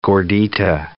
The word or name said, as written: gordita